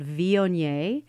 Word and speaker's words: Viognier: So I would say my go-to wine, if it's a white wine at Thanksgiving, is called Viognier. And that, I'm going to spell that. It's V-I-O-G-N-I-E-R.